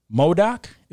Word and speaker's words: MODOK: However, MODOK, is that how it's pronounced? – Mm-hmm. – That's always how I pronounce it, yeah, in my head when I read it.